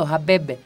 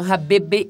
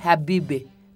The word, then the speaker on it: Habibi: Habibi o Habebe no, Habibi, Habibi, Bueno, pues es que es una IE, ni es I, ni es E, es una. Pronuncian E con la boca de I, Habibe, Habibe, ¿no? Entonces, así suena el árabe.